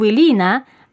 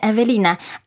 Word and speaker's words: Ewelina: mówią, o, i Wilina, a mówię, nie, Ewelina, a nie, no przecież tu jest Wy.